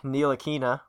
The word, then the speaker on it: Ntilikina: Frank Ntilikina, we're pronouncing that correctly now.